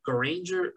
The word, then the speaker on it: Granger: Hawk is Hank Hall, and Dove is Dawn Granger. I can never pronounce her last name right. It's basically Ranger with a G in front of it.